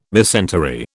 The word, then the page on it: mesentery: British English